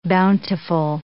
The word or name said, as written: bountiful